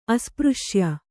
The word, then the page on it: ಅಸ್ಪøಶ್ಯ: aspřśya